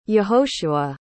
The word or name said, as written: yehoshua